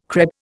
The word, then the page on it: crêpe: Indian